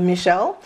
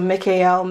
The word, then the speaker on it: Michael: And there are many different pronunciations of his name, such as the French pronunciation of Michel. And what the pronunciation that I'm guided to work with for this invocation, for this praise chant, is Michael. So Michael, Michael, Michael. El being an ancient Hebrew name for God. And Michael also has it at the end, E-L, right? Michael, which is the English pronunciation. And the part Michael or the Micah or the Mika, that part of it is he who is like unto.